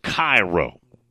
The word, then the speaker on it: Cairo: But it's down there pronounced Cairo.